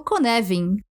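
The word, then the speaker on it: Cynefin: E é aí que entra o modelo Konevin, que se escreve Cinefin, mas se pronuncia, pasmem, Konevin.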